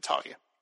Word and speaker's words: Talia: I've always said Talia, but because when they said it on the cartoon series or in Dark Knight Rises, I believe they said Talia.